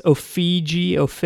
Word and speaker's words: Ofege: The band is called, I think they're pronounced Ofege, O-F-E-G-E is the way you spell it.